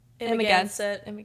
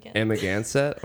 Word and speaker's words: Amagansett: Kentucky, Chicago, Columbus, Pittsburgh, Washington D.C., Nantucket, New York, and how do you say that? – Amagansett. – Amagansett.